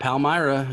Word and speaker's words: Palmyra: Am I saying that right? Palmyra? – Yep, Palmyra.